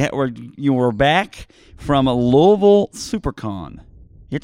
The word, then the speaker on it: Louisville: It's pronounced Louisville because she's, you know, Southern. – Louisville.